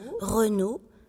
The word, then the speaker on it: Renault: Aussi A-U-L-T. Comme se prononce O. Renault. R-E-N-A-U-L.